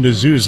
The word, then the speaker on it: Nezuh: Jackson Nazu's last name, by the way, is spelled N-E-Z-U-H, pronounced Nazu.